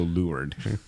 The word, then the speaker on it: leeward: Well, in sailing, there's something called lured as well, which... But it's spelled L-E-E-W-A-R-D, leeward. – Oh. – But sailors say leeward.